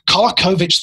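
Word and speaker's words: Kolaković: Um, you start with this chapter on this guy Kolakovich. Is that how you pronounce the name? That's right. Yeah. Kolakovich the Prophet.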